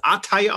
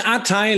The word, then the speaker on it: ATAI: Wir sind bei Art, spricht man das Artei aus? Ich, ich würde sagen Artei Life Sciences.